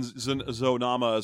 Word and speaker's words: Zonama: Yeah, and then Zonama is pronounced with the exact same cadence as Yo Mama.